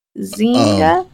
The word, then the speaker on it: Zynga: Game makers from Activision Blizzard to Cigna, I think I'm saying that incorrectly, Zynga? – Zynga. Z-Y-N-G-A? – There you go.